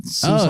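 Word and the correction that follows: Suisun: You mean Sassoon Bay? – Sassoon?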